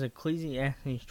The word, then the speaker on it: Ecclesiastes: It is Ecclesiastes 12:13.